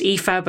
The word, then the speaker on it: Aoife: It was in the UK, and people were kind of a little bit confused about how to say my name because it starts with an A and it ends with an E. But what I always say to people is read it backwards. So, you know, it's pronounced Aoife, but it starts with A and it ends with E.